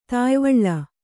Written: ತಾಯಿವಳ್ಳ

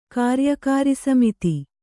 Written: ಕಾರ್ಯಕಾರಿಸಮಿತಿ

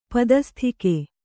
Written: ಪದಸ್ಥಿಕೆ

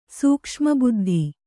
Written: ಸೂಕ್ಷ್ಮಬುದ್ಧಿ

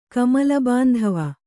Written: ಕಮಲಬಾಂಧವ

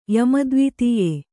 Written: ಯಮದ್ವಿತೀಯೆ

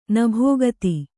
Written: ನಭೋಗತಿ

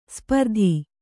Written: ಸ್ಪರ್ಧಿ

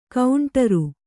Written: ಗಂಡೀರ

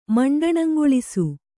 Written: ಮಂಡಣಂಗೊಳಿಸು

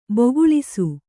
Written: ಬಗುಳಿಸು

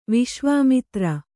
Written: ವಿಶ್ವಾಮಿತ್ರ

